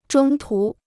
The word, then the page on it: 中途: zhōng tú